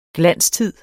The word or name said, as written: glanstid